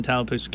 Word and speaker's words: Tallapoosa: In studio, I have from Tallapoosa County. Did I say that right? – Tallapoosa County. That's right.